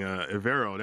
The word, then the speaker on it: Evero: Evero, that's how you pronounce his last name, the defense coordinator.